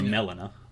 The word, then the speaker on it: Melina: you find out later that girl's name is Melina. Or Melina is how it's said in the game.